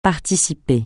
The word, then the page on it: participer: francais